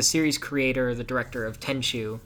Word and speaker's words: Tenchu: So earlier I mentioned the Tenchu thing, which there is some news related to this, but just to warn any Tenchu fans. Am I saying that right? Tenchu? – Yeah, Tenchu.